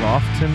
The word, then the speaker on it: often: The T is silent. – Instead of often. – Mm-hmm. It's not often. It's often.